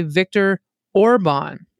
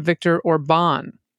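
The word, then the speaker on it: Orbán: Now, second, I'm an American living in Hungary, and I know this is very small, but Hungarian, the first syllable of each word is emphasized. So when we talk about our authoritarian prime minister, we say Viktor Orban instead of Viktor Orban, which is how I heard you say it.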